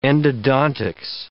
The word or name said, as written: endodontics